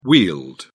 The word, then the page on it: wheeled: British English